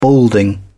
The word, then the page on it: balding: British English